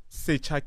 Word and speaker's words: Secha: So one of the partners is Secha Capital. So the main aim is to say, we want to be able to invest in township entrepreneurs. We're not necessarily... – What's the name? Secha? – Secha Capital. – How do you spell that? – S-E-C-H-A.